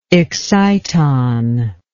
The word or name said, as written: excitonics